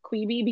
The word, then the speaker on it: Quibi: And I did learn that it's Quibi, not Quibi, because one of the articles I read about it said it rhymes with Libby.